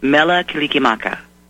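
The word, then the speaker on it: Mele Kalikimaka: Mela kilikimaka. – You got it. That's it.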